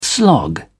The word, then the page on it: slog: British English